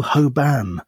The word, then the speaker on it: Hoban: Oh, and I'm rereading a lot of Russell Hoban novels. Yeah. Sorry, Russell Hoban novels. I've been watching a lot of videos of him talking, and he pronounces it like that, so I have to respect him.